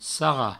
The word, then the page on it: sarrar: sarra